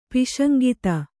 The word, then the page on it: ಪೈಶಂಗಿತ: Kannada